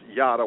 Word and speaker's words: yada: It is a Hebrew word. It's pronounced yada, Yada.